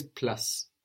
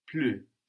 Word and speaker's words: plus: Now, plus is the same word in French, only we will have the ou sound like in tu, like in you, which is tu. And we know that the consonant on the end of the word isn't likely to be pronounced. So, how is plus going to sound in French? – Plus.